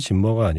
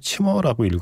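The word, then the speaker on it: Zimmer: 원래는 이름이 한스 짐머가 아니라 한스 치머라고 읽어야 한대요.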